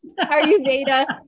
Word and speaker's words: Ayurveda: I said that right. Yeah. Yes, you did. Ayurveda.